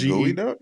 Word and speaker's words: geoduck: Yeah, it's spelled gooey duck. It's spelled GEODUCK, but pronounced gooey duck.